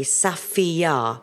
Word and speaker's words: Safiyaa: And it's spelled S-A-F-I-Y-A-A, Safia. – Oh. – It's beautiful. – S-A... – F for Freddie. – Yeah. – I-Y-A-A, Safia.